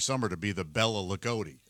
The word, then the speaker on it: Loogootee: L-O-O-G-O-O-T-E-E. – Ligoti? – Ligoti, Indiana.